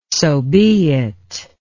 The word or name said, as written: sobeit